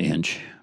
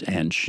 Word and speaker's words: Ensch: And my last name is Inch. – Right. – And most people pronounce it Inch, but it's Inch, you know, in German. But anyway, so it was a E-N-S-C-H.